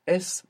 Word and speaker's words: es: And you pronounce the S, which is excellent. English speakers should be aware that maybe they feel themselves pronouncing a Z because many times we have an S in English, like in the word is, and we pronounce a Z. So you might find slipping out es, but this is incorrect. You should be careful to pronounce an S.